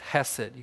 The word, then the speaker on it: chesed: The Hebrew word for this is chesed. You got to spit a little bit to say it right. Chesed.